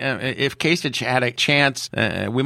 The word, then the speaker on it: Kasich: it, is it Kasich or Kasich? I say I it both Kasich ways. Probably, I think I'm remembering that Kasich is probably the correct pronunciation. I was the one saying Kasich.